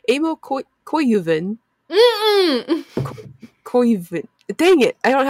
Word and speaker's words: Koivunen: Yeah, loosen your tongue up. – Koivunen. – Yep, there you go. – Okay, like a koi fish.